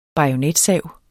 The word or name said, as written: bajonetsav